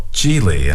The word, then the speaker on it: Gigli: It's called Geely, and it's spelled G-I-G-L-I. – G-I-G-L-I.